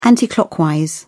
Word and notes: anticlockwise: British English

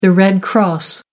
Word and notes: red cross: American English

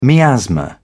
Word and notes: miasma: British English

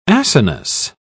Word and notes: acinous: British English

